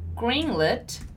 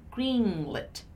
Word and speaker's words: greenlit: the difference in terms of pronunciation of the double E and the letter I, right? So as you probably still remember, when we have double E, it's a long E sound. And when we have the letter I, it's more of a sound. So greenlit, greenlit, greenlit. Okay, so green, long E sound in the beginning, and then lit, lit, lit, a more closed sound, lit, lit, right?